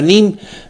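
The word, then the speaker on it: neem: se escribe NEM con doble, así se escribe. Nada más que se pronuncia NIM, como si el par de letras E fuera una sola I latina.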